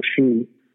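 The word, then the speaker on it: sídhe: The Irish call them the she, which is it's spelled S-I-E-H-E. I believe there's an accent mark over the E, I could be mistaken.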